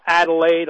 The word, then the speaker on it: Adelaide: But I will spell it for those of you who want me to spell it out. It's A-D-E-L-A-I-D-E. A-D-E-L-A-I-D-E. Adelaide Institute, just like it sounds, .org.